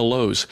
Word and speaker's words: Louhs: So you can imagine that we are on almost every platform that's out there, and they're all under the headings of The Lowes. And that last name is spelled L-O-U-H-S, so it's The Lowes.